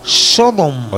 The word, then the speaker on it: Sodom: Así que ahí va Out of the Frontline Trench, que es lo nuevo de los alemanes Sodom.